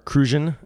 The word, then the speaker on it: Cruzan: So this is Cruzan or Cruzan, as I've been told it's correctly pronounced.